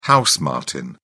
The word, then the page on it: house martin: British English